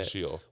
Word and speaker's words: Sheol: Hell, or actually, the word hell technically was never used in the Hebrew Bible, but the word Sheol, I think that's how you say it. – Yeah, Sheol.